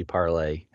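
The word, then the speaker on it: Parler: It definitely should be Parlay. It's the French word to speak.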